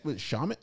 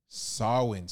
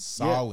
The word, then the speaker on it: Samhain: Is it shaman? It's not shaman. – No, it was like. Smolin or something. Salmon. Hold on. – See, that Celtic is a different thing, too. – Samhain.